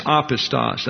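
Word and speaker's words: apistos (ἄπιστος): That's apistos, accent on the first syllable.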